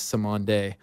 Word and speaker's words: Cymande: The band is Simonde. you'll recognize a couple of the tracks. I played two kind of lesser known ones. The Message is pretty famous, but yeah, it's spelled C-Y-M-A-N-D-E.